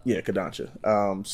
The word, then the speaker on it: Kodansha: Kodansha.